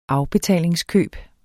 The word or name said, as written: afbetalingskøb